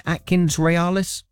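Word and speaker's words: AtkinsRéalis: Have I said that right? Is that right? Atkins Realis? – That's perfect. Yeah, absolutely perfect.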